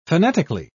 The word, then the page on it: fanatically: British English